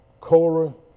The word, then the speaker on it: Korah: K-O-R-A-H. I think in some versions it's spelled C-O-R-E. Am I right? Yeah. Pronounced Korah.